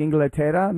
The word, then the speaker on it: Inglaterra: Maybe did he tie things up, equalizer, like Inglaterra, maybe? – No. So that's the way that you would pronounce England in Spanish.